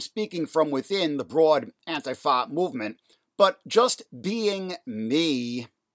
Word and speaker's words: Antifa: You may have noticed what I've been saying Antifa very pointedly, pronouncing it that way, the old school way. When the term first came into widespread currency, maybe about a generation ago, that was the accepted pronunciation, Antifa. Increasingly, the newfangled pronunciation of Antifa is becoming ubiquitous.